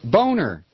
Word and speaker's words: Boehner: Boehner.